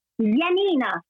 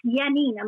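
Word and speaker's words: Janina: So it's Yanina. It's spelled with a J, but pronounced with a Y.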